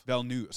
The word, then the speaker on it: Villeneuve: where Villanova... Is that his, how you Villeneuve. – Say his name? Villeneuve. Sorry, Villanova. – Villeneuve.